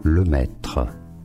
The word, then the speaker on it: le maître: In French, for reference, it is said as le maître.